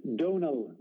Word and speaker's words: Donal: It's Donal with a long O.